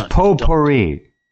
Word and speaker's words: potpourri: So we have got a bit of a potpourri to again tonight. – We've That's potpourri. – Oh, potpourri, is it? – Tea is silent.